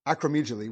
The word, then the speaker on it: acromegaly: Acromegaly, which is basically, it makes everything bigger, like your forehead, the jaw, the nose.